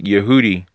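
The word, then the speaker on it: Yehudi (יהודי): And this is going to happen to Jews who do this because Yehudi is how you say Jew in Hebrew because there are no J's in Hebrew.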